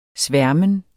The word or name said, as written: sværmen